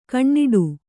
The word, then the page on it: ಗಿಣ್ಣಿಡು: giṇṇiḍu